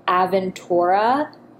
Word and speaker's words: Aventura: It's spelled Aventura, but it's Aventura.